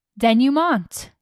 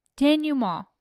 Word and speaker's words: denouement: So Pitchfork says, you seem as interested in the basic forms of storytelling, the way narrative elements play out toward an inevitable denouement. – Denouement. Pronounce none of the consonants, none of the vowels. Denouement. Perfect. Perfect French.